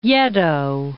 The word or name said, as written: Yedo